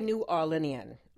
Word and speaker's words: New Orleanian: How do you pronounce that? – We say New Orleanian.